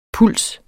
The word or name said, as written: pulse